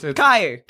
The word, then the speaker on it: Caillou: Caillou looks like it's spelt Galileo. But no, it's Caillou.